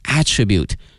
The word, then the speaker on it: attribute: 当然如果说我们在用这个词的时候呢还要小心， attribute 它还有另外的一个词性呢是名词，作为名词的话呢它的发音是 attribute， attribute 啊，希望同学们一定要注意了。